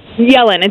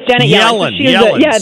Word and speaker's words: Yellen: I mean, he's pointed to some comments by Janet Yellen for the, basically saying that did I mispronounce her name? – Yellen. It's Janet Yellen.